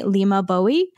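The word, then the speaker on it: Leymah Gbowee: Which I watched like 4 videos to figure out how to pronounce it correctly. So it is spelled LEYMAH and then GBOWEE. So I hope that Lima Bowie is correct. That is how Stephen Colbert said it.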